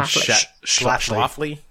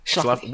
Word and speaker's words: Schlafly: Schlafly. – Schlafly.